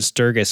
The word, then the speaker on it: Sturgis: The Sturgis Rally, and a lot of people pronounce it Sturgis, but I've verified with the people where the word Sturgis comes from, and it's a hard G. They said, how do you pronounce B-A-G?